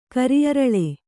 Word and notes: ಕರಿಅರಳೆ: Kannada

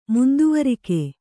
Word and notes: ಮುಂದುವರಿಕೆ: munduvarike

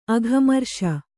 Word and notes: ಅಘಮರ್ಷ: kannada

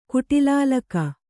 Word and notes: ಕುಟಿಲಾಲಕ: kuṭilālaka

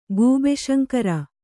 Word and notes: ಗೂಬೆಶಂಕರ: gūbe śaŋkara; Kannada

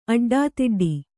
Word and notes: ಅಡ್ಡದುಡ್ಡು: Kannada

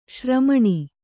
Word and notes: ಶ್ರಮಣ: śramaṇa

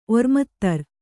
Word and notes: ಒರ್ಮತ್ತರ್: Kannada

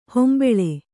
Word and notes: ಹೊಂಭುಳ: hombhuḷa

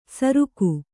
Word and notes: ಸರುಕು: kannada

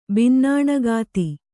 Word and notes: ಬಿನ್ನಾಣಗಾತಿ: binnāṇagāti

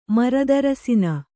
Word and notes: ಮರದರಸಿನ: maradarasina

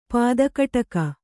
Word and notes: ಪದಕಟಕ: pada kaṭaka

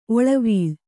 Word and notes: ಒಳವಾಲ: Kannada

